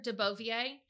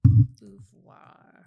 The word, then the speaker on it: Beauvoir: heard De Beauvier. De Beauvoir.